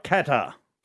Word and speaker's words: Qatar: It doesn't have a G. – It's not a guitar. All right, Qatar. – Qatar.